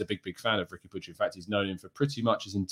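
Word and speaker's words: Riqui Puig: Now, Arsenal have been previously linked with the move for the Barcelona midfielder, Ricky Puig. That is how you pronounce his name.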